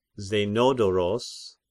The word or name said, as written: Zenodoros